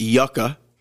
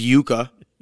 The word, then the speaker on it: yuca: I believe people mispronounce it yucca, which disgusts me. It's yuca.